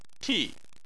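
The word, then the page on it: ti: tone 4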